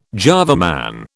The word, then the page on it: Java Man: British English